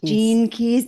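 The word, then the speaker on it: Gene Keys: I don't even know how you pronounce this. Janae Keys guide? – Jean Keys.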